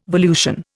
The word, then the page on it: volution: Indian